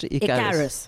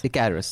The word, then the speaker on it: Icarus: Icarus. – Icarus.